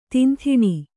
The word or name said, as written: ದಂಧನೆ